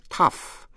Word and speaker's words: tough: Trước hết là cách đọc chữ tough, có âm F ở đằng sau cùng.